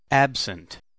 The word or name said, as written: absent